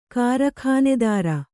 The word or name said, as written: ಕಾರಖಾನೆದಾರ